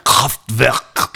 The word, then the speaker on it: Kraftwerk: Kraft, euh, comment ça se prononce déjà Kraftwerk.